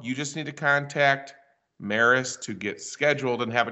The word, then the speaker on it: Maris: The Launching Life's Journeys podcast is going to be in partner on a special camping series with MARVAC, starring Maris, rhymes with Paris.